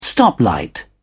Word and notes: stoplight: British English

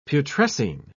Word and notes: putrescine: British English

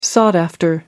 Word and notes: sought after: American English

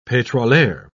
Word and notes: petroleur: British English

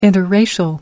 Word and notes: interracial: American English

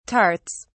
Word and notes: tarts: British English

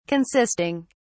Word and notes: consisting: British English

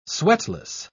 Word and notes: sweatless: British English

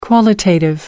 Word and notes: qualitative: American English

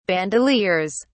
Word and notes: bandoleers: British English

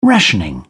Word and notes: rationing: British English

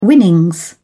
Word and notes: winnings: British English

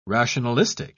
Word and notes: rationalistic: British English

